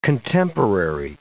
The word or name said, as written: contemporary